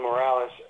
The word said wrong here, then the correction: Moraes: how do you say his name? Moraes. You have to say it.